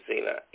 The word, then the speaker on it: Xenia: I'm just expounding up on this to say to you that to have true love that you want to have, Zena, in your life, and this is a Greek word, but it's spelled X-E-N-I-A.